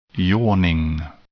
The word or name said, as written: yawning